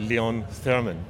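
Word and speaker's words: Leon Theremin: Jeg tror på russisk er udtalt hans navn Lev Termin, og på amerikansk er det Leon Thurman.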